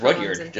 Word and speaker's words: Rudyard: Because I know he was, you know, British, and he wrote I want to say it was Rudyard, just R-U-D-Y-A-R-D, right? – Yeah.